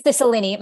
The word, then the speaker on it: Cicilline: Representative, I know he, I know he is retired, but Representative David uh, Ciccolini, am I pronouncing that right? Cicilline.